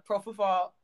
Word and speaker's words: propofol: Prescribed um, uh, propofol, prof, what?